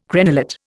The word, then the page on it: crenellate: Indian